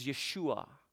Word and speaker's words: Yeshua: The Hebrew pronunciation of Jesus is Yeshua.